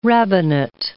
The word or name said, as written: rabbinate